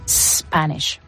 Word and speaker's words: Spanish: Y en inglés tendríamos que decir Spanish. Y luego había gente que también ha pronunciado la S del final como una S española cuando es un sh.